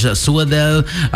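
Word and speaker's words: suwandel: Then you have the traditional range, suadel, kaluhinati. Did I say that right? – Yes.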